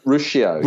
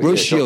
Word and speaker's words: Ruscio: I know you've had some great experts on there in, in relation to the gut, and did you have Doctor Rusko on there? Did you have uh, what's his name? Russ, Doctor Ruskin. Uh, did you uh, have him? Yeah, Ruscio. – Ruscio. Yeah, that's how you pronounce it.